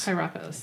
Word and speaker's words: Hierapolis: Hierapolis, thank you.